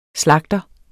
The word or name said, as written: slagter